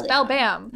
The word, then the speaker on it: Beldam: In half my notes, I just spell it Balbam without the extra ah. L because I'm like, it's Beldam, right? – It's no, Bell-dam. – it's Belbam. Nope.